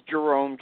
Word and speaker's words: Jerome: I do, but best for anybody to go on my Facebook page, and the last name is spelled, first name is Jerome, J-E-R-O-M-E, last name is spelled H-U-Y-L-E-R,